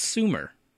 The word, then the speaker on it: Sumer: I think it's Sumer. S-U-M-E-R. – Like Sumerian? – Yeah, S-U-M-E-R. – Okay. So that sounds right.